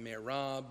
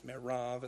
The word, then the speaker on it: Merav: You may pronounce her name Merab, but I believe it's Merav in the original.